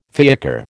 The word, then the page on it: fiacre: American English